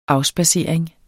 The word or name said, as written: afspadsering